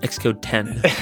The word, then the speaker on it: Xcode X: It's pronounced Xcode 10.